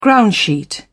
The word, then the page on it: groundsheet: British English